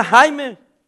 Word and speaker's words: Jaime: Era Jaime. Como se escribe J-A-I-M. Jaime. Jamie. Le dijo el pastor, ábreme como hombre. Le dice, no te llamas Jamie, eres Jaime.